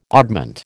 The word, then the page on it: oddment: American English